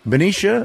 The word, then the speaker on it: Benicia: Is it Benicia? Did I say it right? – Benicia.